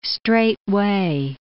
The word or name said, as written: straightway